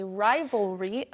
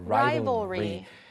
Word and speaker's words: rivalry: We do not have any rivalry against 네. or between us. – 네, rival 하면 라이벌이잖아요. 우리도 그냥 라이벌, 경쟁 상대라고 말하는데 rival 뒤에 L로 끝나지만 RY를 붙여서 발음이 좀 어려울 수 있어요. – Rivalry.